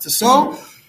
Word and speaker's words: Tissot: The author's name was Tissot, spelled Tissot, pronounced Tissot.